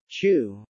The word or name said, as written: chew